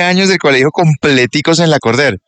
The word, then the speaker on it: Lacordaire: Pero como en francés, como Lacordaire. Sí. Una cosa así. – Es que es un nombre francés y se escribe Lacordaire, pero se pronuncia Lacordaire.